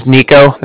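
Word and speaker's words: Niko: My name is Nico. That's N-I-K-O.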